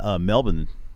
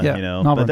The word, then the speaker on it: Melbourne: So they don't say the born Melbourne. – Yeah, they don't say the born like we do in America, Melbourne.